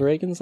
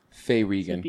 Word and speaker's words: Reagan: I would guess that it's probably spelled the same as Ronald Reagan's last name. – Correct. – Which is pronounced Reagan for Ronald Reagan. – Yeah. – Faye Regan.